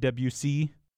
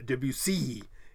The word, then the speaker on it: Debussy: One thing about French is French doesn't have a very strong stress. Sometimes people who do get it correctly will go like, you know, Debussy, with a very heavy stress. It's not. It's a very light stress.